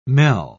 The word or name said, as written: mel